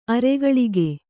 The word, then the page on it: ಅರಗಳಕ: aragaḷaka